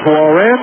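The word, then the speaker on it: Laurent: Here is Lauren Rivard. A silent T on the end of his first name.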